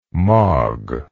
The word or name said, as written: mog